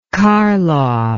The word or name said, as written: karloff